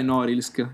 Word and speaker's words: Norilsk: No, si chiama Norilsk, l'hai, l'hai Norilsk. pronunciato male. No, eh, eh, la pronuncia è Norilsk.